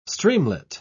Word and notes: streamlet: British English

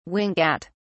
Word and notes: wink at: British English

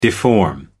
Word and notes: deform: American English

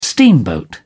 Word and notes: steamboat: British English

